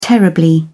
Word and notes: terribly: British English

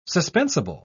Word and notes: suspensible: British English

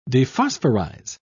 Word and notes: dephosphorize: British English

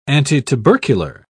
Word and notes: antitubercular: British English

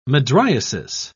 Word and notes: mydriasis: British English

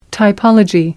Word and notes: typology: American English

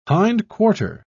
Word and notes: hindquarter: British English

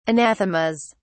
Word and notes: anathemas: British English

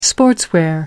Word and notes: sportswear: American English

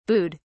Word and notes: booed: British English

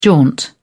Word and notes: jaunt: British English

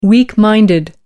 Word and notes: weak minded: American English

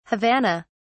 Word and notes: havana: British English

